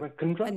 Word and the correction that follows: Irankunda: Nestori Irankunda.